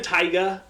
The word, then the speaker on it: Tyga: But the one sister was dating Tiger, or the rapper. – Okay, however you pronounce that. Tyga.